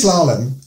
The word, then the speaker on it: slalom: Salon? Slalom? How do you slalom. – Slalom. Is that how you say that word? – Slalom.